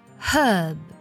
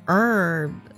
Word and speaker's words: herb: 再来一个单词 herb，这个单词呢指的是草药的意思，在英式的发音当中呢直接会读作 herb，字母 h 呢是发音的，那么在美式的发音当中直接读作了 herb，对，直接把 h 的这个音省掉了，读作 r 的音了， herb herb herb。